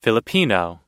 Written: filipino

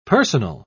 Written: personal

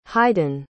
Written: haydn

